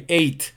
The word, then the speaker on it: ate: Como el número 8 se pronuncia, I ate, ¿ok?